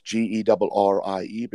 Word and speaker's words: Gerrie: So it's Gerrie, but you need to roll your R's a little bit better than that. So it's and you need to growl on your G's.